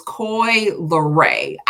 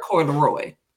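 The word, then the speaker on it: Coi Leray: So I always say her name wrong. It's Coy Leray. I've been calling her Coy Leroy because honestly, I think it's a better name, Coy Leroy.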